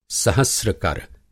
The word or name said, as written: सहस्रकर